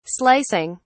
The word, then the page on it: slicing: British English